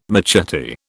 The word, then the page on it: machete: British English